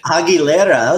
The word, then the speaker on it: Aguilera: And we're really excited today because we'll be reviewing Christina Aguilera's Bionic. – Aguilera, that's a good pronunciation.